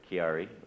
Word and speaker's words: Chiari: William has Carrick, am I saying that right? – Chiari, right, it's a Chiari tumor, and they've been working with that for several years, so you probably remember that.